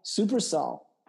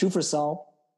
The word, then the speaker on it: Supersol: This week's podcast is sponsored by Supersol. Some people think pronounced Shufersol, but they're wrong. It's pronounced Supersol.